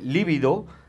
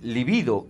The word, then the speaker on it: libido: En efecto, puesto que no, lo que muchas veces se dice líbido, se debería decir libido, que es el deseo sexual.